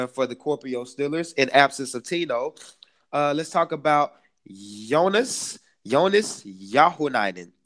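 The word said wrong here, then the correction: Johannes Jauhiainen: If I pronounced it right, it's pretty getting my Finnish better. – Try Johannes Jauhinen. – Johannes Jauhinen. – There you go.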